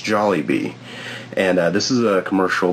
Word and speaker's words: Jollibee: This week's Twitter Theater, I'm still on the Jollibee train, and it's pronounced Jollibee, like it says clearly in the commercial, but last week I kept saying Jollibee or something.